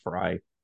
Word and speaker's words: Frey: And so by all means, go check out Purple PTSD, Mr. Josh Fry, spelled Frey, pronounced Fry.